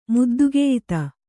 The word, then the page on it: ಮುದ್ದುಗೆಯ್ತ: Kannada